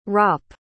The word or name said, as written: ropp